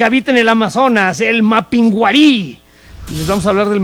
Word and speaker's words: Mapinguari: Ah, no, es que no tiene acento en la I. – No tiene acento en la I. – Mapinguari.